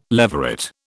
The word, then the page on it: leveret: British English